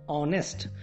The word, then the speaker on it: honest: Okay, what I am speaking, honest, how I am pronouncing, honest. But you need to remember here, the spelling of word honest is what? H-O-N-E-S-T. H-O-N-E-S-T. So here word H is a consonant but producing vowel sounds.